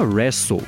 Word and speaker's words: Resso: Bom, e agora eu vou falar sobre a ByteDance, que lançou na Índia uma plataforma de streaming de música chamada Wrestle, que se escreve R-E-S-S-O.